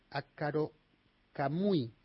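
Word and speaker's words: Akkorokamui: Akaro Kamui. ¿Es así? ¿Se pronuncia así? Perdona mi, mi japonés. sí, sí, sí, está muy bien la pronunciación.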